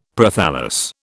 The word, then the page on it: Prothallus: British English